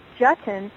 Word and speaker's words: Jutton: So I always say Jutton rhymes with button, on my introductions because it helps the person know, oh, at least I'm not going to mess up their name.